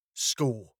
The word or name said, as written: scored